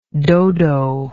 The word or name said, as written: dodoism